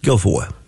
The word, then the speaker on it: Guilfoyle: Yeah, it's pronounced Guilfoyle.